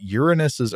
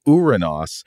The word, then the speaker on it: Uranus: Now more people pronounce it Uranus than Uranos, and such is life. And if pedants come along with an alternative pronunciation, tell them to, quote, stick it up, let's say Uranos, stick it up Uranos.